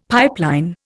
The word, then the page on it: pipeline: Indian